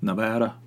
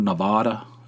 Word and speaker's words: Nevada: They lived up in Reno, Nevada, or Nevada, as some people mispronounce it. Up there near Oregon, as some, I don't understand why people can't pronounce words.